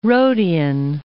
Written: Rhodian

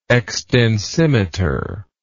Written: extensimeter

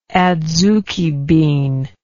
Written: adzuki bean

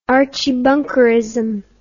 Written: Archie Bunkerism

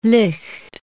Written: licht

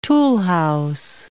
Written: toolhouse

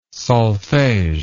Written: solfege

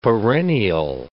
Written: perennial

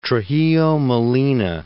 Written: Trujillo Molina

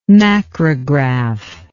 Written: macrograph

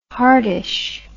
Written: hardish

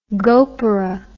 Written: gopura

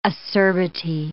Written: acerbity

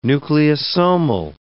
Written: nucleosomal

